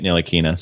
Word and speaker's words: Ntilikina: And by the way, I just saw a video of Frank Ntilikina, by the way, pronouncing it Ntilikina. So I'm going with that.